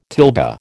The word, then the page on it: tilde: American English